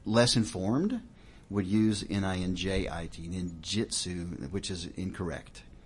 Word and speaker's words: ninjutsu: Well, the art would be called ninjutsu. So, NINJUTSU, ninjutsu. Sometimes you'll see it spelled with an I, ninjutsu, JITSU. That's generally a flag that whoever's purporting that is fraudulent.